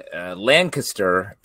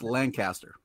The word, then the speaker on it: Lancaster: We were in Lancaster, Pennsylvania, yeah, which is a ways west. And it was pretty fast and loose out there in the mass. – Just so you know, it's pronounced Lancaster.